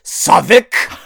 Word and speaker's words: Southwark: And he looked at me and said, "Southwark." I was like, "Not helpful."